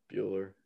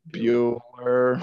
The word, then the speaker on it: Buehler: You spelled it Buckler. – Yeah, I didn't know how to spell it. I just put B-U something something. – Buehler. – Buehler. All right.